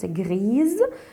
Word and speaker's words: grise: C'est pourquoi qu'on a mis à la fin de l'adjectif de couleur gris un E et on prononce grise parce que c'est un seul S entre deux voyelles. Alors on a le son Z.